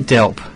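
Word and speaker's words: DELWP: Is that how you pronounce it? – We say Delp. – Delp. – It's just a bit easier. You're right. It is Department of Environment, Land, Water and Planning. So it has the W in there, but just Delp. – For ease, Delp, yeah. – Delp, all right. You forget the W, it's a silent W.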